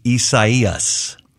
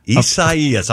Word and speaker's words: Isaias: And it's correctly pronounced Isaias. Isaias. I'm letting you know that, so when you fill in for news and have to report on it, not a chance.